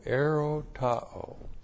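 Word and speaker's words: erotao (ἐρωτάω): And then the verb erotao which looks like that in the Greek, E-R, long O, T, T-A, long O, erotao.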